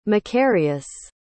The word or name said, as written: macarius